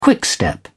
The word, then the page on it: quickstep: British English